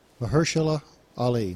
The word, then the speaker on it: Mahershala Ali: Pronounce his name now. – You can. – I worked on it every Say it. – Weekend. – Say it. – Mahershala Ali.